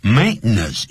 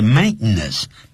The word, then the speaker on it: maintenance: 嗯哼，maintenance 也或是 maintenance 两个发音都可以，但是 maintenance 念快的时候，外国人都会念是 maintenance。